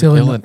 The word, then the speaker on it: Illinois: It's Illinois. S is silent.